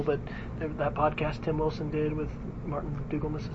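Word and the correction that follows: Dougiamas: is that how you say it? – Dugiamas.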